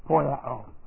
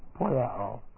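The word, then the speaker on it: poieo: It's poiao. P-O-I-E, long O. Poiao.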